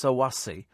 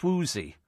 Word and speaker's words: Swoozy: Sue in Hackney, it's not pronounced Sawasi, it's pronounced Swoozy. It's a dance.